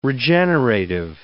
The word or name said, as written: regenerative